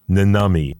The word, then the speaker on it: Nannami: It'll be called Nanami, and that's spelled N-A-N-N-A-M-I. Nanami, and it'll be out on the By Norse label.